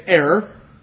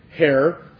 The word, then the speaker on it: heir: Sometimes in our English language, especially as we go back to jolly England, the H is silent and sometimes it's aspirated, but sometimes it's not. And so we say heir. Some people say hair, but it's really not. The real pronunciation of the word is heir.